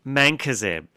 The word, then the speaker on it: Mancozeb: It's called Mancozeb, and it's spelled M-A-N-C-O-Z-E-B.